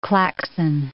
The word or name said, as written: Klaxon